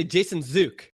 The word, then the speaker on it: Jason Zook: So see, we say it. Zook like we say Took.